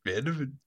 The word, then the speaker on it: Van de Ven: He said it. Van de Ven. Van de Ven. Van de Ven. He's so proud to say it right.